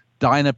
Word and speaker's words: Dina: Phonetically, you are right. It's Dinah, but I call myself Dina. It's short for Geraldine.